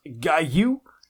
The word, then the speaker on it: Guile: It's pronounced Guile.